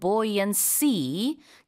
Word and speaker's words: buoyancy: Yeah, what's interesting though, pronunciation wise, a buoy has buoyancy or is buoyant, but in that case, you pronounce it boy. So even in 맞네요. – North America. – 맞습니다. 명사 형태인 buoyancy 그렇게 뜨는 성질은 buoyancy라고 하지 buoyancy라고 하지 않아요.